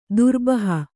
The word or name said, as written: ದುರ್ಬಹ